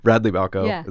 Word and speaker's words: Radley Balko: Radley Balko is how I'm pronouncing it.